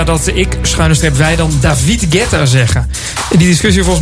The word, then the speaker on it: David Guetta: Maar ja, het is een Fransman, dus dan zeg je het gewoon als David Guetta. – Ja, ik denk dat de meeste Fransen het ook wel zo uitspreken.